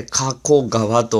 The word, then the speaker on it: 加古川: すね、東京からバイクに乗って、えー、佐世保に向かって走っているわけですが、 1 日目、えー、宿をですね、兵庫県の加古川というところに取りました。えー、ここはですね、加える、そして古い川と書いて、加古川と読むところでございまして、で、一級河川、加古川が流れている。